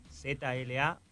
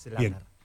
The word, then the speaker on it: Zlatar: Zlatar. Como Zlatan, con la R al final. – Zlatar en vez de Zlatan. – Claro, es Zlatar.